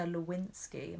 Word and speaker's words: Lewinsky: But podcast-wise, I've started Slow Burn, which is all about the Monica Lewinsky.